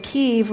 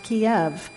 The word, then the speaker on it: Kyiv: This shawl was made when Kyiv was pronounced Kiev.